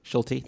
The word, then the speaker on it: Schulte: Schulte.